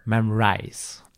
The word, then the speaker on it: Memrise: ok, Memrise.